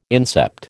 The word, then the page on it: incept: American English